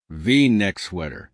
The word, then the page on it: v neck sweater: American English